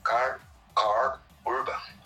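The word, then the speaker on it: Karl Urban: E lembrando que é o, o vilão desse filme do Carl Urban, Carl Or Urban, consegui pronunciar corretamente agora.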